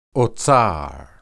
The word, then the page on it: otsar: hebrew